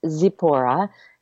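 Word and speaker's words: Tzipporah: So it's actually pronounced Zipporah. And I always say, think of a zipper.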